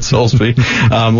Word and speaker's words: Solesbee: It's like the soles of your feet and a bee that buzzes. So David Soulsby.